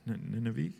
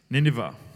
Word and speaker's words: Nineveh: Nineveh, am I pronouncing that right? Nineveh, okay. Nineveh.